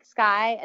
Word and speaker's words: Skye: And I'm like, Sky, as in the blue sky.